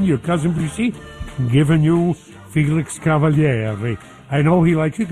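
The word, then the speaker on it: Felix Cavaliere: Cousins, our guest tonight, Felix Cavalieri. And I think I'm one of the only ones that pronounces the name, well, properly, like we would in Sicilian, I guess.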